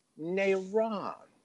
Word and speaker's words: Negron: In most of the world, that G is silent. Nairon.